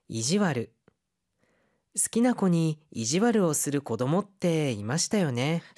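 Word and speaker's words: 悪: 스키나코니 좋아하는 아이한테 이지와르를 する 좀 일부러 못되게 구는 그런 아이들이 있었잖아요. 이마시타요네. 스키나코니 코 이지와르를 して 낚았다리 뭐 네, 시다리. 자, 이지와르 여기에서는 이 악자를 와르라고 읽고 있습니다.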